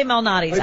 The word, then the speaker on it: Malnati's: The guy that was on talking about pronunciations said Lou Malinati. – Oh, Malinati. – Yeah. – There you go. Way to bust a mic. – Nobody caught it. – But you did. – I say Malnati's.